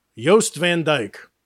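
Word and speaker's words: Jost Van Dyke: Spelled like Jost, like Colin. Yost Van Dyke.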